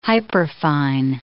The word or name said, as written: hyperfine